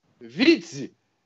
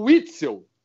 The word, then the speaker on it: Witzel: Chamavam ele de Vitze. Nem o nome dele sabiam pronunciar. Era Wilson Witzel invertiam tudo.